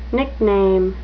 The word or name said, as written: nickname